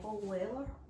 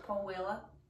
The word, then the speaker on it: Paul Weller: Because you don't really pronounce the L in the Paul, so it's like Paul Weller.